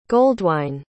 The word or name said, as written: goldwine